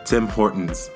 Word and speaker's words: Tim Hortons: Let's see the way she says Team Hortons. She doesn't even know how to pronounce. This is Canada, sis. Tim Hortons. Get it right.